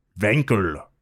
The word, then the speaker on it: Wankel: Felix Wankel. He's German. – Nice pronunciation, dude. – Thank you. W's are V's in German, or they're pronounced like that.